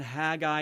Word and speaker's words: Haggai: Haggai is the way it's supposed to be pronounced.